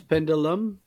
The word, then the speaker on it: Pendulum: Comment tu prononces Pendulum Pendulum, Pendulum ouais, c'est bon.